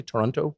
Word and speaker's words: Toronto: Toronto? – Yeah.